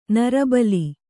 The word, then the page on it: ನರಬಲಿ: nara bali